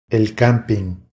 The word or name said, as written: El camping